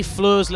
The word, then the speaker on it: Floor: It's like that, floor, like as we put floor, car floor. – Ah. – I'm floored. – So just for clarification, F-L-O-O-R.